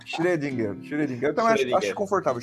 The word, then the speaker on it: Schrödinger: Vamos chamar, pro nosso bem aqui, eu sei que esse O com E é um som difícil pra gente brasileiro pronunciar. Vamos chamar de O Schrödinger ou Schrödinger, o que você achar mais confortável.